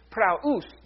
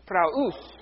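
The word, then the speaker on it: praus (πραΰς): The word we translate as meek is praus. Two syllables. Say it with me. Praus.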